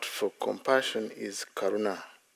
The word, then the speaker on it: karuna: Karuna, long A. Not a short one, so I don't know near dawn. There's metta, karuna, mudita, I think also upeka. But karuna, all those words actually, it's a long A at the end.